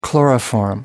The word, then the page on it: chloroform: American English